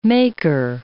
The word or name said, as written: maker